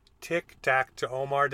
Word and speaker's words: TicTacTOmar: From Offside Hockey Talk sitting down today with the one and only Tic-Tac-To-Omar.